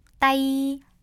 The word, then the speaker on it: tay: 그리고요, 아래요, T-A-Y 있잖아요. – 네. – 요거는 무슨 뜻이냐면 손이란 뜻이에요.